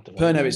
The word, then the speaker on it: Pernod: Perno, sorry, it's P-E-R-N-O-D. It's got a silent D.